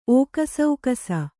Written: ಓಕಸೌಕಸ

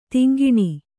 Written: ತೆಂಕಣ

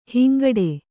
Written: ಹಿಂಗಟ್ಟು

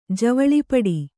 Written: ಜವಳಿಪಡಿ

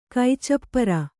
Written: ಕೈಚಪ್ಪರ

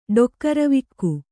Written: ಡೊಕ್ಕರವಿಕ್ಕು

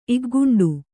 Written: ಇಗ್ಗುಂಡು

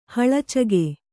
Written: ಹಳಚಿಗೆ

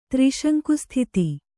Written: ತ್ರಿಶಂಕುಸ್ಥಿತಿ

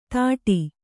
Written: ತಾಟಿ

